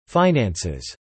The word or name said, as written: finances